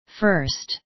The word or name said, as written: first